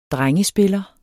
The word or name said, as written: drengespiller